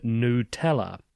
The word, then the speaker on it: Nutella: Our Nutella team wanted to share how we pronounce the iconic spread's name, and we emphasise the new in Nutella.